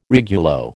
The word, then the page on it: regulo: American English